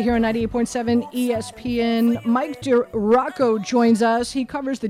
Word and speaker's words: DiRocco: Mike DeRocco uh, Rocco, sorry, Mike DiRocco. I keep on pronouncing it wrong.